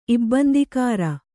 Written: ಇಬ್ಬಂದಿಕಾರ

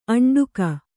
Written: ಅಂಟಿಕ್ಕು

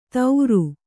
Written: ತೌರು

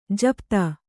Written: ಜಪಿತ

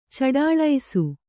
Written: ಚಡಾಳಿಸು

